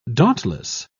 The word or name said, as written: dauntless